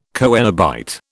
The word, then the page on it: coenobite: British English